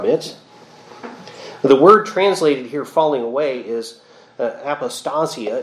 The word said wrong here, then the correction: apostasia: It's actually, I put the emphasis on the wrong syllable there. It's apostasia, from which we get the words in English, apostasy and apostate.